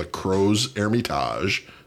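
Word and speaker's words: Crozes-Hermitage: This is from the Croze Hermitage, C-R-O-Z-E-S. And the second word is Hermitage, begins with an H, silent H, E-R-M-I-T-A-G-E.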